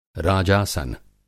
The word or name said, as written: राजासन